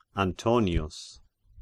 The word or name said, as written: Antonius